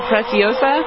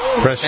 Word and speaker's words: Preciosa: Is that how you pronounce it? Preciosa? Precocia? – I'm thinking Preciosa. – Preciosa. – There you go. That sounds right.